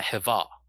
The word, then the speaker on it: Hava: But in Hebrew, Adam and Eve were actually pronounced Adam and Hava, which Hava is what we call it as Persians.